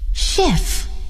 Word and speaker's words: chef: Chef. He's a chef. Chicago. He lives in Chicago. 今天挺有意思，一个字母组合 CH 有三种发音，它们是 CH、 K 和 SH。